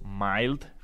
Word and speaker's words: mild: Luego están los mild hybrid. Mild se escribe M-I-L-D.